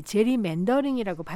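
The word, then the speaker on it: gerrymandering: 그런데 이 게리맨더링이라고 하면은 어느 한 정당에 유리하도록 부당하게 선거구를 조정하는 행위를 말합니다. 미국인들은 보통 이 제리맨더링이라고 발음하는 것이죠.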